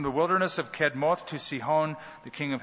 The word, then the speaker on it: Sihon: That's the correct pronunciation. Uh, reads to us like Sahon, but I, I'd prefer to say Sihon because that's the pronunciation.